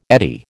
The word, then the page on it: eddy: American English